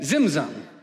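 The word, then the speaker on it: tzimtzum: And it's actually spelled with a T, and so you say like zimzum, but I can't say it that well, so in English we say zimzum.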